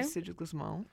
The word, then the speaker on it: Euridice Gusmao: Euridice Gusmao, yeah. – Okay, that was better pronounced. I was hoping you did that. – It doesn't have a G. It's just Euridice Gusmao.